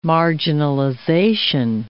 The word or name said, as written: marginalization